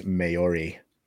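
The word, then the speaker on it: Māori: Lamelli says the pronunciation of that word is Maori.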